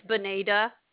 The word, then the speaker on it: Bonita: My name's Bonita. – Can you spell that for me, please? – B-O-N-I-T-A. – B-O-N-I-T-A.